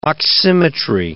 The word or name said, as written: oximetry